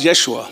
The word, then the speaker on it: Yeshua: In Hebrew, it's pronounced Yeshua.